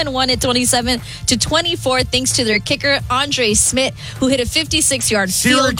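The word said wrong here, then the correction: Szmyt: Is it Schmidt? – Schmidt, yes. It's spelled S-Z-Y-M-Y. – I feel like I've heard Smith.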